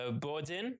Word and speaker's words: boudin: B-O-U-D-I-N. B as in boy, O-U-D-I-N. Would you pronounce that for us? – Boudin. – Love it. – It's Boudin. – Boudin.